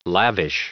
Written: lavish

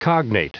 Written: cognate

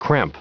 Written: cramp

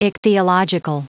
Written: ichthyological

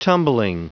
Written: tumbling

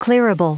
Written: clearable

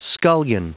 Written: scullion

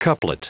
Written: couplet

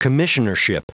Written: commissionership

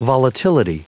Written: volatility

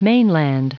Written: mainland

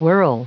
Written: whirl

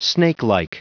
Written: snakelike

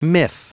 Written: miff

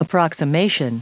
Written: approximation

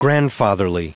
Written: grandfatherly

